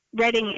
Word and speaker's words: Reading: It's spelled R-E-A-D-I-G. – Correct. – Isn't Reading not Reading? – Well, Reading is the American word, but... Reading is the way it's pronounced for the railroad.